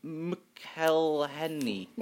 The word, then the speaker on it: McElhenney: Wow, that was not easy for you to pronounce. It's MC. Apostrophe A-E-L-H-E-N-E-Y. McElhenney, I think. – No, sorry. – It's got an E-Y at the end. It's got... – Okay, that's confusing. – McElhenney. Yeah, McElhenney.